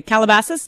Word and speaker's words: Calabasas: And so this happened in California, and it played out on the lawn of a home in is it Calabasas? Am I saying that right? – I, I believe you are. – Okay, Calabasas, uh, California.